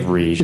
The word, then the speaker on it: Riege: R-I-E-G-E. It's like Rigi.